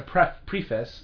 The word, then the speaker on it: preface: And so people, if you've never done a podcast before, just say if you need, next time as a preface, right. – Is it preface? – Preface. – It's spelled preface, though.